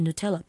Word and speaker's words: Nutella: That sweet treat that people always pronounce it wrong called Nutella, it's actually Nutella.